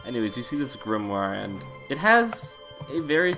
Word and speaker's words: grimoire: It looks Grimoire. – I know as a kid that I pronounced it Grimoire, so I had to make sure. – I mean, there is an I in there, to be honest.